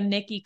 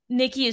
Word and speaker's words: Nickie: My profile and everything is just my name, so Nickie Cobble. Nickie is spelled weird. It is N-I-C-K-I-E.